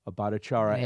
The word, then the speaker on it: Bhattacharya: Bhattacharya. Yep. As defendants. So Bhattacharya.